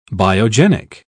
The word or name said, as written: biogenic